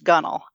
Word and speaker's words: gunwale: I mean, one of them that I noted was the word gunnel, you know, is spelled G-U-N-W-A-L-E. So it's spelled gunwale, but it's pronounced gunnel. And then there's a word, it's spelled forecastle, F-O-R-C-A-S-T-L-E, and it's pronounced forecastle.